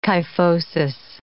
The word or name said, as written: kyphosis